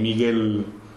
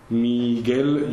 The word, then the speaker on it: Miguel: For example, my name, my second name, Miguel. Miguel, you write it with G and U, G-U-E, but you only pronounce G and E, Miguel.